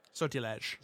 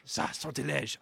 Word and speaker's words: sortilège: L'enfant et le sortilier. – Sortilège. – Sortilège.